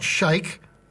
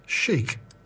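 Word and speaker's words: Sheik: Not shake, it's S-H-E-I-K, but in this case it's pronounced chic.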